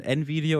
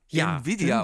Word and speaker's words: Nvidia: Ich weiß nicht, ob es der offizielle ist oder ob die das intern unterschiedlich aussprechen, aber ich hatte die Opening Keynotes, ich glaube, zur CES gesehen und halt der Typ, der Chef der CES dort ist, der dann den CEO von Nvidia begrüßt hat halt ganze Zeit Nvidia gesagt und das scheint die offizielle Aussprache aber zu sein.